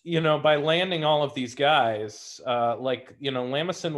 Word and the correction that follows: LeMason: Waller, am I pronouncing that name right? – LeMason? No, I think it's LeMason, but yeah.